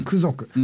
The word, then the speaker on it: 空族: カウントダウン目前ですね、はい、今週末、上映するのが、映像制作集団、うん、映像制作集団クゾク、うん、空族って書いてクゾクって読ませるんですけども、の作品 2 作品を上映します。